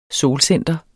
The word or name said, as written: solcenter